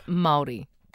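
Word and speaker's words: Māori: Is it pronounced Maori? – Maori. – Pardon? – There's no H in it. It's Maori.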